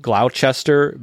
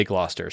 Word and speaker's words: Gloucester: It's spelled Gloucester, but they say Gloucester, so we're gonna say Gloucester.